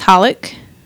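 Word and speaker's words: xxxHOLiC: Triple X Holic. – I think it's just pronounced Holic.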